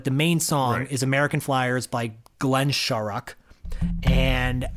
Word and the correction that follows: Shorrock: it's pronounced Shurik. – Sorry. – Because he's Australian and that's how they pronounce it there.